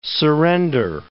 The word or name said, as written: surrender